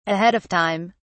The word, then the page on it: ahead of time: British English